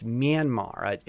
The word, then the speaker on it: Myanmar: So, well, Myanmar is a different syllable. It's Mya. It's Myanmar.